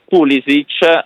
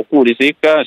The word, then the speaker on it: Pulisic: Ordoñez invece è squalificato, nel Milan l'unico assente, anzi due assenti sono Rabiot e Jimenez, c'è invece Pulisic o Pulisic, si può pronunciare come sapete in entrambe le modalità,